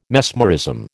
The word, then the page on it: mesmerism: American English